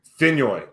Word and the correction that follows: Fenyo: Fenyo, like Fenyo. – Fenyo. – Yo, like the E is completely silent.